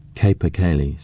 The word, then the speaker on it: capercaillie: "Capercaillie," said Warwick.